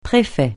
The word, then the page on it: préfet: francais